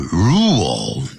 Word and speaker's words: rule: rule 这个新的规定 rule 不要念成 rule、啊、叫 rule 那个 L 在尾巴的时候叫像念欧洲的欧但舌尖要跳起来、啊、跳起来然后呢抵在呢、嗯、上牙齿上排牙齿的后头